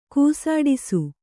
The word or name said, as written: ಕೂಸಾಡಿಸು